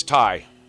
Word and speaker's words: Theye: It's Theye.